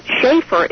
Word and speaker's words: Schaefer: And that Schaefer is spelled S-C-H-A-E-F-E-R.